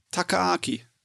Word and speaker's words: Takaaki: Äh, auf jeden Fall, ähm, ja, wie gesagt, äh, TMS Entertainment Studio 6, Regie führt Taki. Tak wie spricht man das aus? Stopp, wo ist er? Wo ist er? Takaaki. Taka, ah, Takaaki.